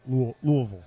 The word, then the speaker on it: Louisville: and they pronounce it Louisville.